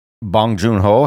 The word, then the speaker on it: Bong Joon Ho: But uh, uh, uh, Bong Joon Ho, have I said his name properly? Is that his name? – Yes.